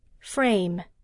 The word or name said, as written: frame